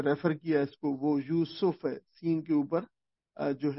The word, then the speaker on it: یوسف: تو یوسف عام طور پر رکھا جاتا ہے، کہا جاتا ہے، لیکن قرآن مجید کا جو تلفظ ہے اس لفظ کا وہ یوسف ہے، سین کے اوپر جو ہے وہ پیش ہے، یوسف.